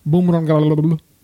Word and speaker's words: Bumrungrad: So the vast majority of the clientele that frequent Bumrungrad International Hospital, that's exactly how it's pronounced, by the way.